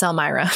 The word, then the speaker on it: Elmira: She had to move back to Elmira. Elvira? No, it's Elmira. Sorry. That was a funky one. Like, the pronunciations online were all over the place.